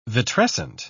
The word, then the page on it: vitrescent: British English